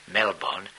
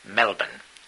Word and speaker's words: Melbourne: I learned, too, that you don't pronounce Melbourne Melbourne, but Melbourne.